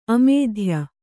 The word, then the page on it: ಆಮಾತ್ಯ: āmātya